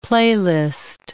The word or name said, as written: playlist